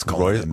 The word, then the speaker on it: Cullinan: Is that how you pronounce it? I'm too poor to pronounce it. I can't pronounce Cullinan. Rolls-Royce Cullinan.